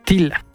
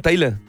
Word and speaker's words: Tyla: Tila. T-Y-L-A. – Ah, Tayla.